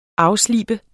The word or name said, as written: afslibe